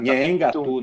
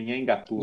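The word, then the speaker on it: Nheengatu: Como é que fala isso? Ingato. Nhengatu, né? Nhengatu. – Nhengatu, é.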